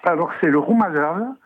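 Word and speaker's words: romazava: Alors ça s'écrit Romazavao. – Très bien. – Mais en malgache, on ne prononce pas le AO. – Ah bah non, en malgache, on ne prononce pas le AO. – On termine sur le V, le roumazave.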